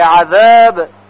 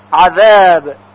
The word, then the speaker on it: عذاب: باز توجه باید داشته باشید که در کلمه عذاب ذال هست که باید تلفظ بشه، یعنی سر زبان به نوک دندانهای ثنایای بالا برخورد میکنه. عذاب عذاب. پس اگر بگوییم عذاب با زه غلط هست.